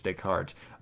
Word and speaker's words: Descartes: that one always fools me, it's Descartes,